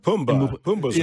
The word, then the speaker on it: Pumbaa: Pumbaa's right.